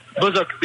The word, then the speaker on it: Bozok: Bozok, B-O-Z-O-K, Bozok.